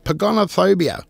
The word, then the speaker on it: pogonophobia: Now, do you know what a pogonophobia. Pogonophobia. That's how it's pronounced. Not pogo sticks.